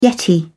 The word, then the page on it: yeti: British English